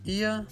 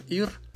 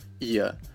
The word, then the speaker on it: ihr: Люди, которые учили немецкий в школе, часто говорят, что вот нас учили говорить просто «вир», «ир». Но, как вы понимаете, в школах не всегда уделяют внимание лучшему варианту произношения. Поэтому на самом деле должны эти местоимения произноситься именно так – «виа», «иа».